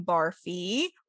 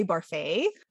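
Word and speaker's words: Barfée: or as he would say, Barfay, a recent transfer. – With an accent. – With an accent, yes. There's an accent on the E. But which E? There are two E's in his name. It's the first E.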